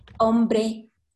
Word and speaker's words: hombre: For example, hombre, hombro. Hombre means man, hombro, shoulder. Or the pronunciation, yes, it's tricky because the vowels is a clue.